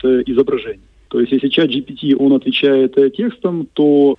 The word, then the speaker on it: ChatGPT: Значит, э, чат GPT, правильно говорить.